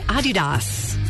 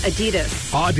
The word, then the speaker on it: Adidas: Did you know that most of us pronounce Adidas wrong? It's actually supposed to be Adidas. – Really? – Yeah, slight emphasis on the first syllable. Not Adidas. – Adidas. All right. – Yeah. That's German. It's a German company, so.